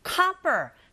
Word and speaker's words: copper: 이런 경우에도 드 사운드는 R 사운드에 가깝게 발음이 들리게 되고 그렇게 하시면 되고 copper, 자, O이지만 O 자체의 철자에 강세가 가게 되면 O가 아닌 아 소리로 발음하게 되죠.